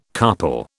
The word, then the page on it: carpal: British English